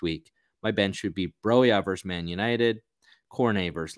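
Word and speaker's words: Broja: And my bench is Sanchez, Broya, who I also looked up. It's Broya. That's how you pronounce his name.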